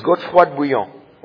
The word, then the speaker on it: Godefroy de Bouillon: I can say it right because I'm French. His name is Godefroy de Bouillon, okay?